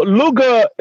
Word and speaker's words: Lugha: So Luga is a Swahili word that means languages. And, you know, it's spelled L-U-G-H-A, Luga. You know, that's Swahili. Mm-hmm. You know, but coming from Uganda, we use the L-U-G-A because we are a Bantu, so we don't have the H in our expression.